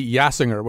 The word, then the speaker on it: Yassinger: Yeah, the name of the singer is Amy Yassinger, which kind of rhymes with jazz singer.